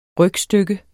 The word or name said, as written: rygstykke